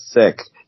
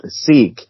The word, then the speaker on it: sic: or by a word it says sick, it actually is pronounced seek.